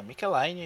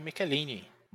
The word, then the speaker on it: Michelinie: Porque você me passou um link de um podcast muito bom do Aranha, em que ele é entrevistado, e aí ele explica que a pronúncia do nome dele é essa, Michelaine. Ele aceita também o Micheline, mas que o mais correto seria o Michelaine.